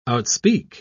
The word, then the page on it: outspeak: British English